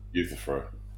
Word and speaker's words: Euthyphro: Is it Euthyphro? How do you say that? – Euthyphro.